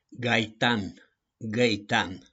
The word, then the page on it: GAITAN: GAITANI